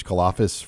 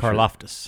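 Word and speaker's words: Karlaftis: They're going to see another elite All-American defensive end this week, and George Karloftis from I butchered his last name. I'm pretty Karloftis. – Sure. Karloftis from Purdue.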